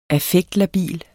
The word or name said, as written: affektlabil